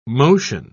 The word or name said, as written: motion